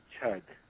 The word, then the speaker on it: chud: So it has to be chod, not odd. Is what that's saying? – It has to be chud, yes. – Chud, yeah, sorry, I mispronounced that.